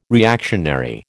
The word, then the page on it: reactionary: American English